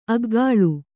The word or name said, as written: ಅಗಳು